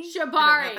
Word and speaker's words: shibari: Shibari. – I said that right. – Yes. No, it's shibari.